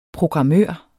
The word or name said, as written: programmør